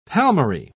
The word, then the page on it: palmary: British English